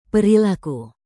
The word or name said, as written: perilaku